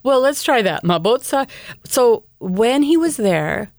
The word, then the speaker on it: Mabotsa: It was called Mabotsa, M-A-B-O-T-S-A, Mabotsa. And when he was there, my African pronunciation is not very good.